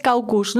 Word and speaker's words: caucus: Lo que nosotros, claro, tú lo has pronunciado genial en inglés, caucus, ¿no?